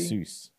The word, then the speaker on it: rhesus: A racist a monkey? Rhesus. – Oh, a, a rhesus monkey.